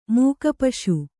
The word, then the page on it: ಮೂಕಪಶು: Kannada